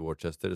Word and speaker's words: Worcester: Enten så vet du at det heter Worcester, eller så Men du visste det.